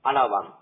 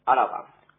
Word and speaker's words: あらわん: あらわんって言うんですけど、らの、あらのらのところが、小さい感じで発音してください。あらわん。